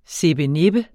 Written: SIPPENIPPER

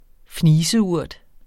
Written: fniseurt

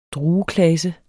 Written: drueklase

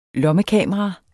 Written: lommekamera

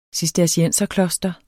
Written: cistercienserkloster

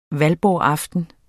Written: valborgsaften

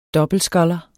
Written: dobbeltsculler

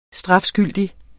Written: STRAFSKYLDIGT

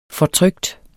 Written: fortrykt

